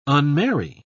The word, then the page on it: unmarry: British English